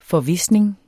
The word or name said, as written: forvisning